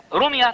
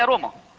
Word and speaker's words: روما: روميا طبعا هي روما، لكن ده النطق القديم بتاع الترجمة القديمة. روميا دي نطق قديم، لكن هي روما اتأسست سنة 753 قبل الميلاد.